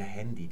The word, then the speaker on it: Handy: Und dieses I wird an das Wort Hand angehängt, das deutsche Wort Hand, H-A-N-D. Jetzt werdet ihr vielleicht einwenden, aber es heißt ja Handy. Dieses Ä ist ja die englische Aussprache von Hand, also eher das englische Wort Hand.